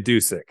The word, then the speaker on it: Dusick: It's Ryan Dusick is the pronunciation, D-U-S-I-C-K.